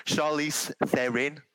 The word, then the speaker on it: Charlize Theron: Because it's South African, it's Theron. Isn't it? – It's Charlize Theron.